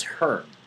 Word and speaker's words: Hearn: So they'll play on Friday in Herney, Texas. Or actually, it's Hearn. The E is silent.